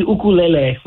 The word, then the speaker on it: ukulele: Yeah, it would be ukulele would be the correct way to say it.